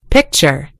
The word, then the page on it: picture: American English